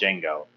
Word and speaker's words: Django: But he was in a Quentin Tarantino movie called Django. The D is silent.